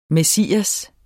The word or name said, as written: messias